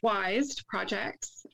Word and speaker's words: YZED Projects: Yeah, so it's pronounced YZED Projects.